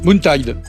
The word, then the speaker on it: Moontide: Moontide. – En plus, vous le prononcez bien.